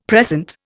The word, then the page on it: precent: Indian